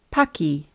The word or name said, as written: paki